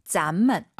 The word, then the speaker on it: 咱们: 발음은 조심하세요. 삼성경성 아하 我们 이성경성 咱们。 – 아하 咱们 음정이 달라요.